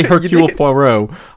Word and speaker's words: Hercule Poirot: Hercules Poirot, the Belgian detective. I got much crap for that, Charlotte. I know how to say Hercule Poirot. I just, like I know how to say it.